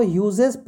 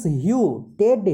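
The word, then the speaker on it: Hughes: अपना जो है म्यूट कर लो टेड ह्यू T E D H U जी एच ई एस ये एच यू जी एच ई एस है जनरली लोग इसको यूजेस पढ़ते हैं वो उसको यूजेस मत पढ़ो the correct pronunciation is ह्यू टेड ह्यू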